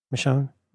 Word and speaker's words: Michonne: So like right after Michonne, or how do you say it? Michonne. – You got it. – Michonne.